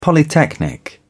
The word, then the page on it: polytechnic: British English